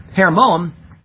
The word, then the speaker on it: Hermon: Hermon, or as it's pronounced, Hermon, is introduced here, and it will be seen using this name 13 times in Scripture.